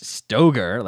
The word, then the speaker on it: Stoeger: And if you Google it, it's not even Ben Steger, not spelled the same right. It's Stoger, like S-T-O-E-G-E-R.